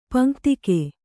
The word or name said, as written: ಪಂಕ್ತಿಕೆ